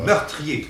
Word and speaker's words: meurtrier: Meurtrier. Oui. Et actuellement, c'est trois syllabes.